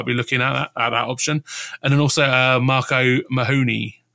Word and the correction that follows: O'Mahony: although I've definitely put the emphasis on the wrong play there. I made him sound Spanish. Mark O'Mahony from Brighton.